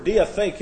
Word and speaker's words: diatheke: The word covenant is the Greek word diatheke. It looks kind of like this, D-I-A-T-H-E-K-E, diatheke. This is D-I-A-T-H-E-K-E. Those are long E's.